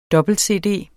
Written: dobbelt-cd

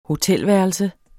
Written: hotelværelse

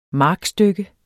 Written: markstykke

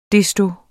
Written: desto

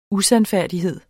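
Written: USANDFÆRDIGHED

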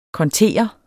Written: kontere

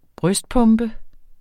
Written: brystpumpe

